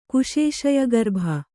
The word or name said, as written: ಕುಶೇಶಯಗರ್ಭ